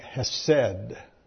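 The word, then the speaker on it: hesed (חֶסֶד): Loving kindness is a Hebrew word and probably pronounced something like hesed.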